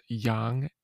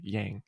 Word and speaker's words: Yang: Yeah, it's Yang, actually. He's Yang. Chinese, and that is just how you pronounce his name. But if you want to be, like, super white, then yeah, Yang. It's Yang.